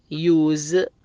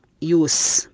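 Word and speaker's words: use: نرجع بس إلى كلمة اللي هي حرف الإس تنطق بحرف الزد، مثل إذا أتى طبعا قبلها حروف متحركة مثل كلمة يوز ما تنطق يوس بالسين، لا تنطق بحرف الزاء، وأنا حطيت عليها علامة جنب الكلمة، على كل كلمة حطيت عليها علامة نجمة، وحرف الزد معناه أن الإس ينطق هنا بحرف الزاء.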